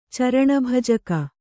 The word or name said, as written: ಚರಣಭಜಕ